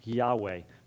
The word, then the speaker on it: Yahweh: And I remind you that when you see Lord in all caps, that's God's personal name, and it's a name that we pronounce Yahweh.